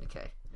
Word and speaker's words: Nikkei: did an interview with Nikkei newspaper in Japan. I think that's how you call it. How you say it, Nikkei.